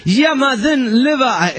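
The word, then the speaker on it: Ja, må han leva: Es que se dice así: "Jamaden leva, jamaden leva".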